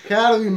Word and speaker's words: Harvey: ¿Lo pronuncié bien? Harvey. – Harvey.